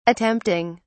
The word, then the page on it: attempting: British English